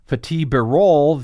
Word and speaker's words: Fatih Birol: Fatih Birol, the agency's executive director, did I say it right? – Yes.